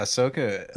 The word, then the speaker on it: Ahsoka: Ahsoka, right. Or how do you say her name? – Yeah, Ahsoka.